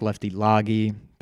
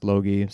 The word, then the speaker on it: Logie: Looks like Lefty Loggy, but it is pronounced Logie.